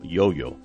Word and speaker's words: yo-yo: Emily lo abre, es un yo-yo que aquí se pronuncia sin acento, yo-yo.